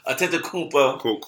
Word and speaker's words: Antetokounmpo: Giannis Antetokounmpo. My pronunciation game is crazy. – Antetokounmpo.